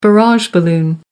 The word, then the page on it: barrage balloon: American English